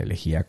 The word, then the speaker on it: elegía: Una elegía con acento en la I, ¿vale? Para que lo tengamos claro.